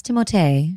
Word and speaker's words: Timothée: Kylie Jenner and Timothy, although I believe the correct pronunciation is Timothée Chalamet. – Timothée. – He is part French and speaks fluent French and has an accent over the first E of his name.